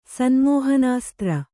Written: ಸನ್ಮೋಹನಾಸ್ತ್ರ

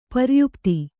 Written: ಪರ್ಯುಪ್ತಿ

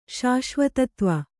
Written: ಶಾಶ್ವತತ್ವ

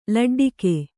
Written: ಲಡ್ಡಿಗೆ

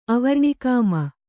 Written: ಅವರ್ಣಿಕಾಮ

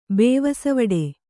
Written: ಬೇವಸವಡೆ

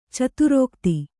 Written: ಚದುರೋಕ್ತಿ

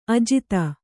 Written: ಅಜಾತ

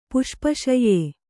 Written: ಪುಷ್ಪಶಯ್ಯೆ

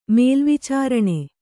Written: ಮೇಲ್ವಿಚಾರಣೆ

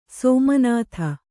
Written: ಸೋಮನಾಥ

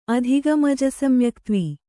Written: ಅಧಿಗಮಜಸಮ್ಯಕ್ತ್ವ

